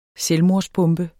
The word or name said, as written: selvmordsbombe